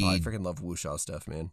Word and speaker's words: Wuxia: but Wuxia, W U X I A. It borrows elements. – Wuxia. – Wuxia. Okay.